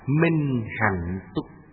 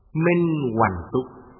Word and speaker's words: minh hạnh túc: Đức hiệu thứ tư là minh hạnh túc. Ngày xưa hạnh cũng đọc là hoằng, minh hoằng túc. Đọc hạnh là danh từ, đọc hoằng là động từ, là hành động, là động từ.